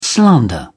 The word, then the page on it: slander: British English